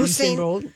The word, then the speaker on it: Usain Bolt: Usain Bolt, yeah.